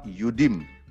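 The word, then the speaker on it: Yudim (יהודים): Demikianlah tulisan kata Yehudim, berarti dibacanya Yehudim atau malah lebih tepat Yudim.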